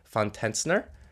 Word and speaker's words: Tetzchner: Von Tetzchner.